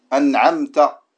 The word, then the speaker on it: أنعمت: Yani la prononciation correcte de ta en arabe, c'est de dire ta. An'amta. An'amta. Et dans notre dirigeant, on rajoute un petit signe. On dit an'amta. Ta. An'amta. Ce qui est plus correct, c'est de dire « an'amta ».« An'amta ». Lakin, ne pas la prononcer comme un « ta » non plus. De ne pas dire « an'amta ». C'est entre les deux.